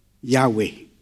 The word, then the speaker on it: Yahweh: If you were to look at the overhead slide, the original text is YHWH, Yahweh. And because it's hard for English people to pronounce it, we add an A and we add an E in to make it Yahweh.